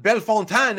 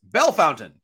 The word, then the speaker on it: Bellefontaine: And then it's not Bellefontaine, it's Bellefountain. So Bull Duke pronounces it as a Bellefontaine. Wrong, it's Bellefountain, actually.